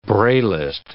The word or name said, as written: braillist